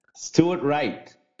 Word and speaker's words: Stuart Wright: Stuart Wright.